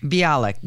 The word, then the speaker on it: Bialik: I think it's Mayim Bialik. – Bialik, yep.